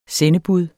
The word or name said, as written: sendebud